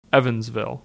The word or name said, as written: Evansville